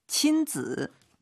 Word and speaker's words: 亲子: 亲子 발음 지 이게 삼성으로 발음해야 됩니다.